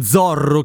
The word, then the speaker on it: Zorro: Mi chiede il patron Zorro, che in spagnolo si legge zorro e vuol dire volpe, come fanno i polpi e in generale gli animali a cambiare colore della propria pelle per mimetizzarsi?